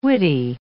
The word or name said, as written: widdy